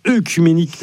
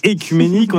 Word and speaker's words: œcuménique: Merci Clara, et nous sommes le 8 octobre et c'est donc l'anniversaire de l'ouverture du concile écuménique de Chalcédoine. Le 8 octobre 451, alors concile écuménique, ça peut sembler une expression un peu barbare. Déjà précisons qu'on ne dit pas œcuménique, mais écuménique.